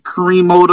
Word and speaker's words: Crimo: Crimo the third.